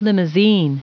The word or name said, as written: limousine